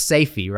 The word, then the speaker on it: Sayfie: It's Sayfie, right? – Yep, that's correct.